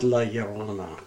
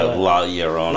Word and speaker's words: La Llorona: It's spelled La Llorona, but I think it's pronounced La Llorona. Cause it's yeah, La Llorona.